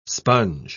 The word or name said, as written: sponge